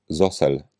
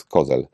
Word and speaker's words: Cozel: Książkę napisał jezuita, ksiądz Felix Zosel. Tak mnie pouczono, że wymawia się jego nazwisko, chociaż pisze się Cozel. Ja to wymawiałem zawsze Felix Kozel, ale podobno wymawia się Zosel.